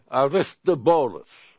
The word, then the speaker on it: Aristobulus: This is how Soteris taught me how to say it, the Greek way. Aristobulus. Doing okay? – Aristobulus.